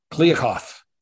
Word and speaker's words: Kliavkoff: Kliavkoff.